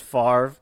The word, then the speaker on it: Favre: It's one of those things, like, with these names, it's kind of like the Brett Favre conundrum. Like, he says it's pronounced Favre, but that doesn't make any sense based on how it's spelled.